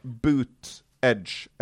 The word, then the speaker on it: Buttigieg: og da står det boot Edge, altså støvel, kant. Så det da booted, altså booted edge.